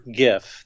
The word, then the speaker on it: GIF: I personally prefer GIF, the hard G pronunciation.